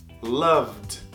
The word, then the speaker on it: loved: Loved. É um D suave, não é loved. Loved. Não é loved. Se tu fala loved, você está pronunciando o E. O E não pronuncia, lembre-se disso.